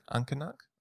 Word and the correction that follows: uncinate: You spell it for me. – UNCINATE. – Uncinate.